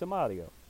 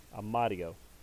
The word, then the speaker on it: Amadio: And Michael Amadio, it's Amadio. And even though I'm slightly mispronouncing that, it's a D, not an R. Amadio.